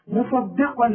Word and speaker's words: مصدقا: خد بالك في عندك صاد عاملة زي الجبل وقاف عاملة زي الجبل وبينهم دال رؤياء، فالدال دي هتطلع منك باب اذا ما خدتش بالك من نطقها. لازم تقولي مصدي ان الدين عند الله الاسلام. شوف الدال بقى إن الدين أي مصدي مصدقا مصدقا لما معهم.